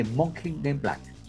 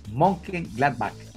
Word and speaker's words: Mönchengladbach: La pronunciación correcta es el Borussia de Mönchengladbach. Mönchengladbach.